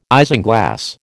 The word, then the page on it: isinglass: American English